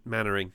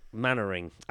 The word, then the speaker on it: Mainwaring: Station Officer Steele was inspired by Dad's Army character Captain Mainwaring. – Mannering. – Mannering. You know what? You know when you see something written down and you have no idea how you pronounce it, so you just give it a good old college try?